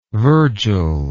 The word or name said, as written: virgil